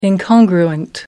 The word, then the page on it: incongruent: American English